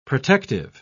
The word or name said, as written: protective